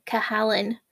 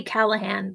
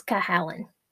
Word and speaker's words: Cahalan: I want to say Callahan, but it is not. The H and the L are switched for that. So it's C-H-A-A-L-A-N. I think it's Cahalan.